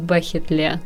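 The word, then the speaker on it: бәхетле: Только мы привыкли говорить бахетле. Но это, видимо, особенность произношения. – Да, потому что в татарском на 6 букв больше, чем в русском алфавите.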